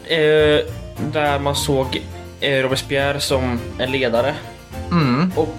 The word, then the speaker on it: Robespierre: Skräckväldet är ju en period där man ser eh, jag tror han heter Robespierre. – Ja, Robes Robespierre.